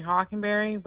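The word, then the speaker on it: Hawkinberry: It might be under Brittany Hockenberry, but I know that's my maiden name, but I would have used Stage.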